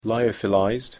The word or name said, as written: lyophilized